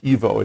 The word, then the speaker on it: Evo: And so I replaced it with Insta 360 Evo, and I believe it's pronounced Evo. It's capital E, capital V, capital O.